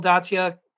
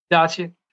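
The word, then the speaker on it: Datsyuk: This year's induction class, along with Shea Weber, includes Jeremy Roenick, Pavel Datsya. I can't ever pronounce his name. – Datsya.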